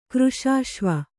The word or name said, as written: ಕೃಶಾಶ್ವಿ